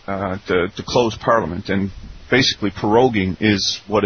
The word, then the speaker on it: proroguing: Now, what exactly is proroguing parliament? – Proroguing. – Yeah. – It's pronounced. It's kind of pronounced like pierogi.